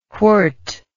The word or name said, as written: whort